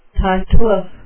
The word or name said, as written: tartuffe